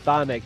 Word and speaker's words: Chthonic: Okay, I'm not familiar with that one, but they were pronounced Thonic, but they were spelled like Cthulhu, C H T H U O.